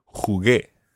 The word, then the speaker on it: jugué: You would spell that J U G U E acute. Jugué. – Jugué. – But the U is silent in there. Well, the second U, the first U obviously has to be pronounced, but the second U is silent. Jugué.